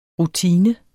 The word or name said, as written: rutine